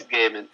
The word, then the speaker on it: Gaiman: It's definitely, as much as we shouldn't trust Joel on how to pronounce people's names, it's Gaiman.